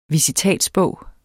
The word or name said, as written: visitatsbog